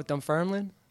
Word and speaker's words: Dunfermline: Oh, Dunfermline.